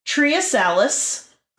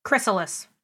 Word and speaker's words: Chrysalis: Next week, we're going to talk about Triasalis. – Chrysalis.